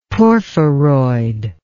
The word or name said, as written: porphyroid